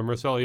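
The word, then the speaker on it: Myrcella: Marcella, yes.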